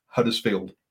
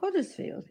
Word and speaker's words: Huddersfield: My mum is from Yorkshire, and here she goes to tell you how to say Huddersfield. – Don't say Huddersfield or Huddersfield. It's Huddersfield. – Huddersfield. – Now, that sounds really stupid. So you don't do an H, you do Huddersfield.